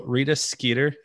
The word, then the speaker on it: Rita Skeeter: One thing I realized by the end of the book, I never had these feelings in the movie, but so Rita Skeeter, is that the right way to pronounce her name? – Yep.